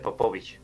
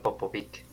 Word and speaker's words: Popovic: Señor Smith, se pronuncia Popovic, no Popovic.